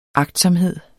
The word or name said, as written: agtsomhed